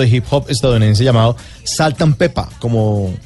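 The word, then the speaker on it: Salt-N-Pepa: Sí, se escribe con, con ah. N intermedia, pero no dice ant, sino ah, saltan pepa.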